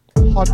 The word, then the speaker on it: Haute: Hot. You gotta say it differently. It's spelled H-A-U-T-E. Hot.